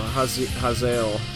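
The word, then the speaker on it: Hazael: This band, I'm not quite sure how to say it. Haziel? It's H-A-Z-A-E-L. – I think you're probably right. – Azael is like a demon. – Yeah. – So Haziel is probably how you say it.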